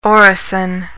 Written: orison

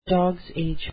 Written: dogs age

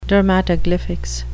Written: dermatoglyphics

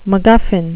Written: mcguffin

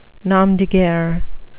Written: nom de guerre

